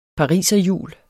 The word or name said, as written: pariserhjul